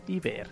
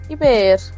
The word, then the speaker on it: Iver: O, ¿cómo se dice? ¿Iver o Iver? Iver, ya. – Iver. Como se lee, así dile.